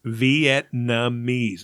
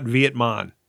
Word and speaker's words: Vietnamese: It's not Vietmon. – I didn't say that, did I? – You said Vietnamese. – Vietnamese. How do you say this? Vietnam. – Vietnamese. – Vietnam, right? – The N comes before the M. And you say Vietnamese.